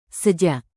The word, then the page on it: sejak: indonesian